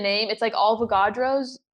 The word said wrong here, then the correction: Alvarado's: Bakery. Have you heard of it? Alvarado's? – I don't know.